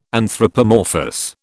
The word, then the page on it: anthropomorphous: British English